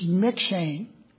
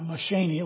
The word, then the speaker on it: McShane: But he's McShane. That's how you pronounce that. Not McShaney, like my reader pronounces it. McShane.